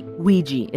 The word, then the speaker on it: Ouija: But yeah, in the final syllable of Ouija, the ja has an influence of the E sound in the first syllable, we, right?